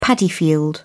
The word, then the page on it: paddy field: British English